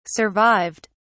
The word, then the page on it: survived: British English